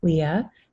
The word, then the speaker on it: Lia: So my name is spelled L-I-A, Leah, and last name is James, like Jamestown, Virginia.